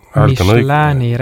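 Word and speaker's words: Michelin: Michelini restoran vist peaks olema õige hääldus.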